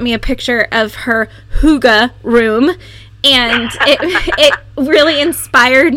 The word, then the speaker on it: hygge: One of the words that kind of sticks out is this term, hygge, I guess is how we figured out how to pronounce it. We actually looked it up to be sure we were getting it right, but it's spelled H-Y-G-G-E.